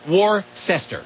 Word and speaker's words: Worcester: But it's not spelled Worcester. It's spelled Worcester. – I know, but it's pronounced Worcester.